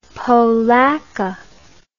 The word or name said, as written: polacca